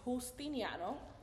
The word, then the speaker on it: Justiniano: So my true pronunciation is Justiniano. – Oh. – The J in Spanish, you know, it's like a sound. Like an H sound.